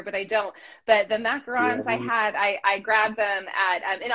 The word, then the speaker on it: macaron: Yeah, it's tricky because it's, you macarons. Know, it's one vowel difference. But the macaroon is the one that's, you know, surrounded by coconut. Whereas the macaron is, you know, the little sandwich cookie.